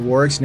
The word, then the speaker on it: Warwick: On Facebook, you can look for us at Crucible Leadership, and on LinkedIn, you can look at us for Warwick's name at Warwick Fairfax with the silent W in the middle, W A R W I C K, at Warwick Fairfax on LinkedIn.